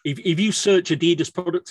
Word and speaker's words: Adidas: If you take Adidas or Adidas, depending on how you pronounce it, where you are in the world. For me, it's Adidas.